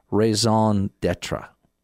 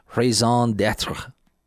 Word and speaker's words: raison d'être: I tried to get my daughter to help me with the pronunciation. Raison d'être. She said, use the back of the throat. I'm from Kentucky. It's the best I can do.